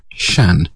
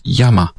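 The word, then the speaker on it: Shan (山): This is the Chinese character Shan, which means mountain. It's the same character in Japanese, where it's pronounced Yama.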